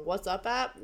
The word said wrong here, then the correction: WhatsApp: WhatsApp? – Oh, WhatsApp. – WhatsApp.